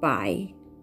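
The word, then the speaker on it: 百: なのでいい場合いい場合 100 という漢字はですね、あの、同じ漢字を、全く同じ漢字を書くんですが、発音記号は BAI で第 3 声になります。低く沈もうとになりますので、 by, by というふうに発音します。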